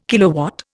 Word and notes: kilowatt: Indian

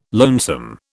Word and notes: lonesome: British English

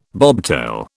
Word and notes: bobtail: British English

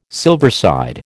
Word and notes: silverside: American English

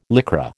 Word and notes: Lycra: American English

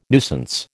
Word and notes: nuisance: American English

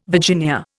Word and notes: Virginia: Indian

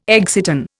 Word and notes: exciton: Indian

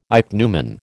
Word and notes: ichneumon: American English

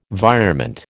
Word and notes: virement: American English

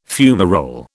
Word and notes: fumarole: British English

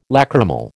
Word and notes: lachrymal: American English

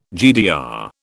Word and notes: GDR: British English

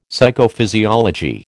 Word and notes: Psychophysiology: American English

